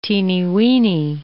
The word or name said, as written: teeny-weeny